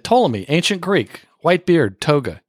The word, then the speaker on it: Ptolemy: You know, Ptolemy is spelled with a silent p, and and trap is spelled with a silent p.